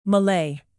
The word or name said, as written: malay